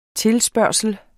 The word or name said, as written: TILSPURGT